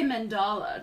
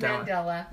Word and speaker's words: Mandela: I always say mandala to sound fancy, but is it Mandela? – It's Mandela. I say mandala too, but everybody gets mad at me when I say it like that.